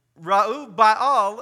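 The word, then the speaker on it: Jerubbaal: who is actually called Reub Baal, is how you pronounce his real name,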